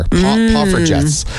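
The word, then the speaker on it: Poffertjes: Pofferjes? – That sounds about right. – So they're a small Dutch pancake is what they are. Pofferjes.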